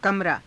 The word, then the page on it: kamaraa: Hindi